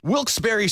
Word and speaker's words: Wilkes-Barre: Now, I had heard that it was supposed to be pronounced Wilkes-Barre. – Wilkes-Barre Scranton's 97.9X. The only way to pronounce Wilkes-Barre.